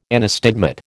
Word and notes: anastigmat: American English

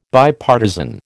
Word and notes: Bipartisan: American English

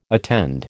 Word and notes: attend: American English

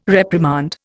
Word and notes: reprimand: Indian

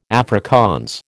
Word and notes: Afrikaans: American English

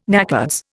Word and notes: NACODS: Indian

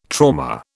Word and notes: trauma: British English

